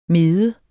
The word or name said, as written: MEDEN